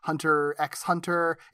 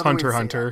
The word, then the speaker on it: Hunter x Hunter: I think the X-Men one was the one he primarily wrote on, along with animes like Rent-A-Girlfriend, Hunter x Hunter. Is it Hunter x Hunter, or like is it? – It's just Hunter Hunter. It. – Hunter Hunter, okay. – The X is silent for some reason.